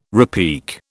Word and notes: repique: British English

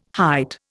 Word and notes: height: Indian

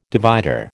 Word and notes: divider: American English

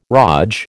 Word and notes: raj: American English

